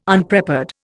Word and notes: Unprepared: Indian

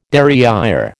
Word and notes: derrière: American English

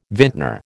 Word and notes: vintner: American English

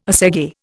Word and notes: assegai: Indian

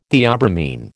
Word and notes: theobromine: American English

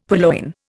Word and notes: purloin: Indian

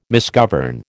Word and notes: misgovern: American English

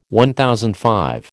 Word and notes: MV: American English

